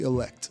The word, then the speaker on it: Illect: it's actually Illect. All right, so I L L E C T. The tagging was always correct. It's just me pronouncing it seemed to, was the difficulty apparently.